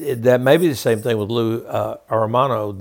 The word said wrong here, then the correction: Anarumo: That that uh, and I'm not saying his name right, uh, Anarumo. That maybe be the same thing for Lou Anarumo.